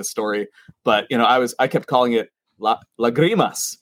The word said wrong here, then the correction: Lágrimas: right? And everyone at the distillery was like, no, man. Like your white dude Spanish is not working right now. It's Lágrimas.